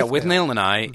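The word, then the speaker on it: Withnail and I: Now, you have another DVD you recommend here, With Nail and I. Am I pronouncing that correctly? – Yeah, With Nail and I is a cult English film from the late 80s, and it's Richard E.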